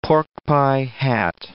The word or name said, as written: porkpie hat